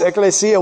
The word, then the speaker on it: Ecclesia: Ecclesia. – Oh, nice. Ecclesia.